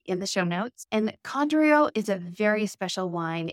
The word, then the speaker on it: Condrieu: So Condrieu on the label, which is spelled C-O-N-D-R-I-E-U.